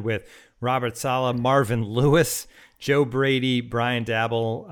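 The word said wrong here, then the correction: Daboll: Eric Bieniemy. Dable, sorry, I did it again.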